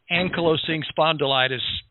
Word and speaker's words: ankylosing spondylitis: Tell us, what is that hard-to-pronounce ankylosing spondylitis condition? – Well, so, you know, you pronounced it perfectly.